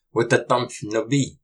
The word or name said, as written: Wutatamsh nupi!